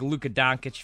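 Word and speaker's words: Dončić: Everybody knows I'm a big Luka Doncic fan. My favorite non-Buck. I'm Slovenian, so I say the correct pronunciation.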